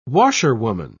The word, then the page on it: washerwoman: British English